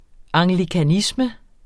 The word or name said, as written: anglikanisme